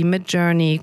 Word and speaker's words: Midjourney: Ich habe gesehen, ihr arbeitet zusammen mit, ich hoffe, ich spreche die alle richtig aus, OpenAI, Anthropic, Suno, Perplexity, Midjourney, Cognition, 11 Labs, LangChain, Pinecone. Habe ich was falsch ausgesprochen? – Die sind alle perfekt tatsächlich.